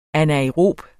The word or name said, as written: anaerob